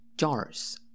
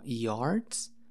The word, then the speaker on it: jars: Y si lo pronuncia así como una J más suavecita, como yards. Yards significa yardas, como las medidas del deporte de fútbol americano, yardas. Pero jars significa jarras.